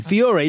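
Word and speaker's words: Feore: First of all, the doctor is, I love the actor, Colm, I don't know how to pronounce it. – Fiore, is that right? Yeah.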